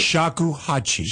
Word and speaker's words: shakuhachi: Shakuhachi.